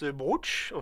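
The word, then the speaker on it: brocciu: Alors, on le prononce brooch, en fait, avec un ou, donc brooch.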